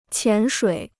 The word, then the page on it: 潜水: qián shuǐ